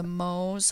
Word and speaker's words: Mohs: This is where the Mohs hardness scale comes in, and it's spelt M-O-H-S.